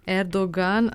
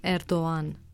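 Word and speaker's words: Erdoğan: Z G, Erdogan ali neslišno Erdogan.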